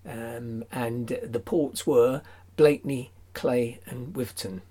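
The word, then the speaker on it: Cley: And perhaps I better say at this point that I pronounce it Clay and not Cly. That's because when I went up in the 1970s to do some research up there, it seemed to me that everybody there said Clay.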